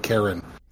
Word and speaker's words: Chiron: I got Pluto and Karen. – I've heard it pronounced both ways, so I went the way that sounded more like Karen with a K, because...